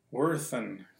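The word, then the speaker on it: Werthan: Driving Miss Daisy is the story of Daisy Werthen, that's how you say her name, who went out for a drive one day and wrecked her car.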